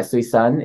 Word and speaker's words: Suisun: Southeast, in case people don't know where Suisun Valley is, it's spelled S-U-I-S-U-N. So it's not Suisun, it's Suisun.